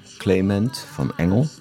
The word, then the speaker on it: Clement van Engel: Te gast in de studio van Radio Bontekoe, Clement van Engel.